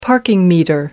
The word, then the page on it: parking meter: American English